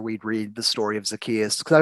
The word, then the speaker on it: Zacchaeus: That's how you say it. That's right. Thank you, someone who's actually read the Bible before to pronounce the biblical names. I'm like, Zacchaeus. – It's Zacchaeus. He's the one from the, I know him.